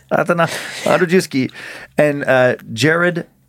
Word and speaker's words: Andrzejewski: This name, A-N-D-R-Z-E-J-E-W-S-K-I.